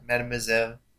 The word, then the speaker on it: Mademoiselle: Mademoiselle. – Mademoiselle. That's four syllables. That's a lot.